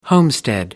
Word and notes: homestead: American English

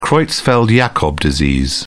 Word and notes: creutzfeldt-jakob disease: British English

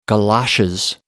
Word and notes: galoshes: American English

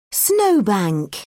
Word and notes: snow bank: British English

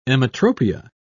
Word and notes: emmetropia: British English

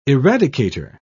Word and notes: eradicator: British English

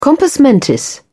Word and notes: compos mentis: British English

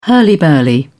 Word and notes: hurly burly: British English

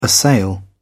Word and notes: assail: British English

